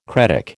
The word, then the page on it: cretic: American English